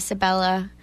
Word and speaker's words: Sabella: Last name is Sabella, S like Sam, A B like boy, E L L A.